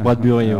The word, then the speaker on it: Bradbury: On reçoit les Bradbury, c'est ça On prononce ça comme ça Alors, euh, j'ai pas ouvert leur micro. C'est parti, voilà, allez-y. – Voilà, ça se prononce Bradbury.